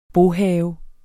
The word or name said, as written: bohave